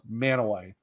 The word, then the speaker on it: Mantua: Because for like Manaway, it's actually looks like Mantua, like M-A-N-T-U-A, but it's pronounced Manaway.